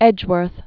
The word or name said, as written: Edgeworth